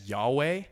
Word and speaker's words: Yahweh: And so I pronounce that as Yahweh.